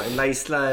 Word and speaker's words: La Isla: Vissa säger Ayla, att det uttalas Ayla. Det beror på vilket språk. – La Isla. – La Isla är nog om det ska vara spanska.